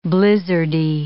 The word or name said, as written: blizzardy